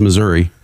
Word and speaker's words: Missouri: We need to pray for Andy because he's in the middle of misery. I'm sorry. It's pronounced Missouri.